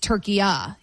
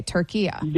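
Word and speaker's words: Türkiye: Okay, so it's not what we would call, you and I are making it a hard A, turkey A, and it's turkiya, he's saying.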